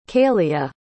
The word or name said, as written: caelia